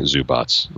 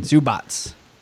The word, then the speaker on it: Zubac: And I want to thank for pronouncing Zubats, not making me do it. Thank you. – I think that's going to be your favorite NBA name by the end of the season. Zubats. – How do you pronounce the first name even? – Ivica Zubats. I know you struggle with those European C's.